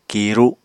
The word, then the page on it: kiru: Apatani